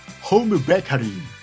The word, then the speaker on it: ホームベーカリー: ホームベーカリー。 – ええ何何何。 – ハウムベーカリー。